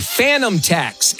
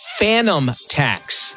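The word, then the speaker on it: Fanum tax: Phantom tax. And it's spelled F-A-N-U-M. Phantom tax. Phantom tax.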